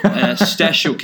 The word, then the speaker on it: Stasiuk: Stasiuk is the Stasiuk.